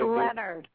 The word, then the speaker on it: Lennard: How do you pronounce your last name? – Yeah, I didn't want to butcher your last name again without checking. – It's Lennard.